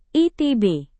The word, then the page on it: itb: indonesian